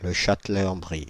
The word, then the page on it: Le Châtelet-en-Brie: France (Île-de-France); [lə.ʃɑt.lɛ.ɑ̃.bʁi]